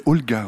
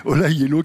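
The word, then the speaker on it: Ola Gjeilo: Ça s'écrit Gelo, mais on prononce ça Yelo. Moi, je disais Olga au début. Non, c'est Ola Yelo. Ola Yelo,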